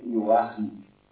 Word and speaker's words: Joachim: em alemão fala-se Joachim, assim com a garganta. E o J é sempre tem som de I.